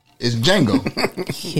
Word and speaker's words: Django: The J is silent. – It's Django. – Yeah. – She said, but that's, I know, mm-hmm. But the D is silent. How can you not hear a D? – The it's Django, like, baby.